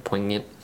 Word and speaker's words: poignant: And I think that's how you say that word, poignant. – Yep. – Poignant. – it's if try it at home. Poignant. It's hard to say. – And got that silent G in there.